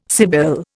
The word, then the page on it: sibyl: Indian